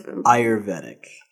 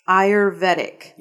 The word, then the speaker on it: Ayurvedic: Are you Ayurvedic. – Ayurvedic.